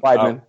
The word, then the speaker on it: Weidman: Am I saying that right? – Yeah, Weidman.